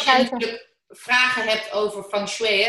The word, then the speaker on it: Feng Shui: Debbie Weesenburg, Feng Shui, want zo spreek je dat uit. Feng Shui, zeggen mensen wel. – Dus Feng Shui.